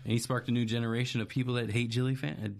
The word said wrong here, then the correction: Jimmy Fallon: Jimmy Fallon.